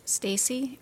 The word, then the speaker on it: Stacie: Stacy, S-T-A-C-I-E, Hebert, H-E-B-E-R-T.